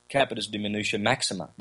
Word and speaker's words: capitis diminutio maxima: Capitis diminutio maxima. That's it. I forgot when I said it wrong last time. It's capitis diminutio maxima, capitis diminutio media, and capitis diminutio minimum, minima, or something like that.